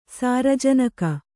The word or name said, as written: ಸಾರಜನಕ